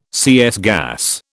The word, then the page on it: CS gas: British English